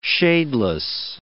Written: shadeless

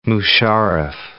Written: Musharraf

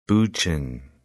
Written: Bucheon

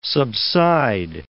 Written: subside

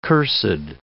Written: cursed

